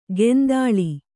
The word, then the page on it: ಕೆಂದಾಳಿ: kendāḷi